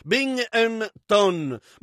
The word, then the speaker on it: Binghamton: B-I-N-G-H-M-T-O-N. Binghamton, eh? Ed è un... Voglio pronunciarlo Binghamton. B-I-N-G-H-A-M-T-O-N, vedete? Binghamton. Ma lo chiamano proprio Binghamton.